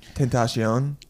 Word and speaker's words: XXXTentacion: XXXTentacion is, and apparently he pronounced his name Tentacion.